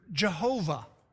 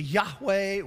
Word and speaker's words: Yahweh: which is part of what gives us the pronunciation you've heard Jehovah. It's really Yahweh with the vowels from Adonai.